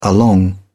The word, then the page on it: along: British English